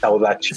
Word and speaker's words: saudade: El portugués tiene saudachi, saudachi se escribe saudade.